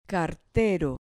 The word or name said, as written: cartero